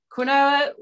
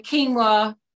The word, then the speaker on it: quinoa: So we call it quinoa when it's growing, and then quinoa when we're selling it. – Oh, that's fun. – So quinoa when it's costing money and quinoa when it's making money.